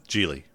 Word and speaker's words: Gigli: Is that how you pronounce that? – Yeah, Geely. – I thought it was G-I-G-L-I. – It is. – Oh, so the other G is silent? – I guess so.